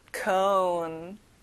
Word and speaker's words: cone: 자, O 발음을 조금 둥글게 해야 돼요. cone.